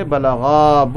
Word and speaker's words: balagha: And we have to pronounce this alif properly. If we pronounce without alif balagha, it will become like a singular, like one person. So we have to say balagha, both of them.